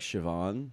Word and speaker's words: Siobhan: But well, because in Irish, BH is V. And MH is V. – Oh, like Siobhan? – Yeah, it's pronounced with a V.